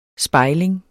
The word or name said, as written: spejling